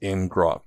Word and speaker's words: ngrok: So Charlie has looked up the FAQ, and it's pronounced ngrok, because grok is understand in.